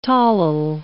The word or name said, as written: tolyl